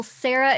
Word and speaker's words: Sarah: No, well, Sarah is kind of a hard one to mess up.